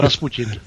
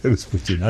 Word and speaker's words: Rasputin: Čte se to Rasputin. – Jo, jasně. Rasputin, ano, přesně tak.